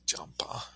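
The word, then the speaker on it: Champa (བྱམས་པ): Champa. It's pronounced way different than it looks, Champa.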